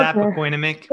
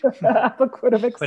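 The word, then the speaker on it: Appoquinimink: Sure, we just say APO, but it's okay. Apoquinamic. – Apoquinamic.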